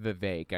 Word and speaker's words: Vivek: I'm sorry, his name's pronounced Vivek. I keep forgetting.